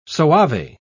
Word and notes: soave: English; British English

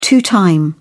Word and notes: two time: British English

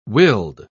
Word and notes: willed: British English

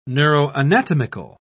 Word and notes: neuroanatomical: British English

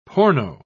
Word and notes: porno: British English

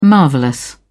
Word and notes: marvellous: British English

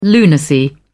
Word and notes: lunacy: British English

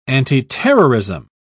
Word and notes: antiterrorism: British English

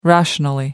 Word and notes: rationally: American English

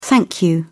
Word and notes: thank you: British English

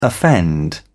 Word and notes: offend: British English

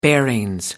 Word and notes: bearings: British English

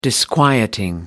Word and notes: disquieting: American English

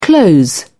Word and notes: close in: British English

